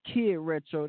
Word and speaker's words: Kiid Retro: I started off Welcome to Turntable Thursdays with me, Kiid Retro. That is K W I D R E T R O.